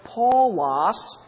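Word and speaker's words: Paulos (Παῦλος): It's pi, alpha, upsilon, lambda, omicron, sigma, and it's pronounced Paulos.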